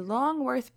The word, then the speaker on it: Longworth: And the next day, I actually didn't get to go to a lot of the conferences because I was going to the Longworth building. And I think I said it right this time. I kept calling it the Longhorn building. And Sarah kept correcting me.